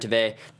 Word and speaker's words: Tveit: Because Gareth, who, again, played by Aaron Tveit, thank you again for correcting my pronunciation, Mackenzie, in our chat room.